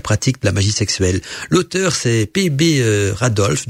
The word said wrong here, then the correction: Randolph: donc Randolph, ça s'écrit R-A-N-D-O-L-P-H.